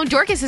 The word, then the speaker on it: Dorcas: I assume that's how you say her name. It's D-O-R-C-A-S.